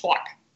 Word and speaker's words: Tawk: There's a great free one I love called, this is going to sound so New York, Twawk, T-A-W-K dot T-O. – T-A-W-K dot T-O? – Yep. So it's like talk to, but it's pronounced Twawk.